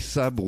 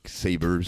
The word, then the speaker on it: Sabre: Eh oui, Maverick Sabre ou Maverick Saber, si vous préférez le prononcer à l'anglaise, qui était parrainé à ses débuts par le rappeur-chanteur Plan B, hein, qui était d'ailleurs déjà passé par les Transmusicales en 2005.